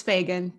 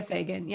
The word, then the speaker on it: Fagan: It's Fagan. Tia Fagan.